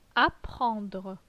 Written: Apprendre

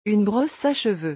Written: une brosse à cheveux

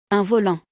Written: Un volant

